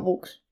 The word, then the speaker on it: bucks: No, books. Así. – En tal. Inglés book es libro. – Books. Así se escribe. No se escribe con doble. Se escribe B-U-C-K-S.